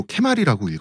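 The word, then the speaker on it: 케마리 (蹴鞠): 그런데 이제 한자를 이제 얘네는 또 케마리라고 읽어요.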